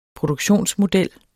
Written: produktionsmodel

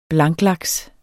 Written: blanklaks

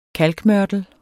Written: KALK

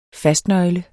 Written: fastnøgle

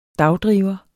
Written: dagdriver

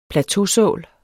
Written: plateausål

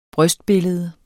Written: brystbillede

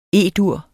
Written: E-dur

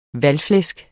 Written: valgflæsk

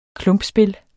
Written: klumpspil

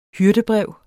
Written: hyrdebrev

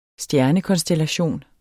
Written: stjernekonstellation

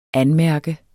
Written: anmærke

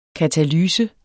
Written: katalyse

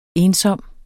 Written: ensom

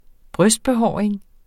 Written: brystbehåring

